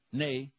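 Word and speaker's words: nee: How do you say yes in Dutch? – Yeah. – Yeah. Really? – Yeah. – They weren't just making that up? – No, I was not. Nay. Nay is no.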